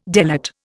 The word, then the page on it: delate: Indian